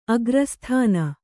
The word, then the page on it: ಅಗ್ರಸ್ಥಾನ: kannada